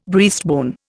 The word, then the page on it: breastbone: Indian